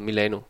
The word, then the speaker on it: Milano: Udtaler man det korrekt, Milano? – Det er jo, kan man sige, den italienske måde, så Milano på dansk, ja. Det er rigtigt.